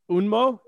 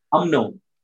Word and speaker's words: UMNO: What about, because that first, the party, the first party that the UN, I know you're UNMO, is that how you say it? – UMNO.